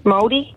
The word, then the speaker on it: Māori: Maori? Māori? – Oh. And you pronounced it beautifully.